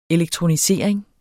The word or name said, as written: elektronisering